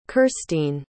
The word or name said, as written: kirstine